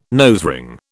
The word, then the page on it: nosering: British English